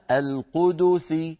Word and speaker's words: القدس: إذن القدس بضم الدال.